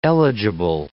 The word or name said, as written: eligible